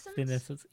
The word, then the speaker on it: senescence: Senescence? – Yeah, senescence. I had to look that up. It's like evanescence, so I got it straight away.